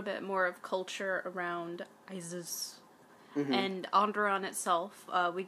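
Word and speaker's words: Iziz: Well, they say it like Isis, but it's I-Z-I-Z.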